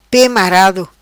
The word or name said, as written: Pe Marado